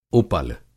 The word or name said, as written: उपल